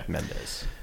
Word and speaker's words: Mendes: Universal DreamWorks, directed by Sam Mendes? – Yes. – That's how you pronounce it? Okay. – Mendes.